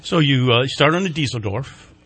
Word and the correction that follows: Dieselhorst: Am I pronouncing that right? – Dieselhorst. – Dieselhorst. – Yes. – That's right. Yeah.